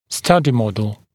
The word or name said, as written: study model